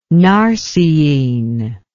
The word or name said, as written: narceine